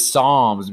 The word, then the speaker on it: Psalms: It's Psalms, because she keeps... – I can say palms. – Yeah, palm. Don't judge me. You don't pronounce the P.